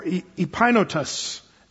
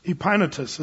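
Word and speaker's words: Epaenetus: He then goes on in verse 5, salute my well-beloved, and his name is pronounced in the Greek more, Epainotus. Epainotus is actually is the way you say it.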